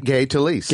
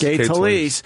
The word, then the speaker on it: Gay Talese: Gay Talese. – Gay Talese.